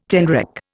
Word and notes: tenrec: Indian

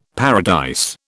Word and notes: paradise: British English

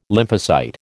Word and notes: lymphocyte: American English

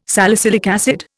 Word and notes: salicylic acid: Indian

